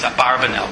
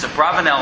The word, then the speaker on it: Abravanel: Abravanel on that pasuk, as you may know is Abarvanel, but his correct name pronunciation is Abravanel on that pasuk says,